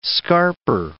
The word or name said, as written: scarper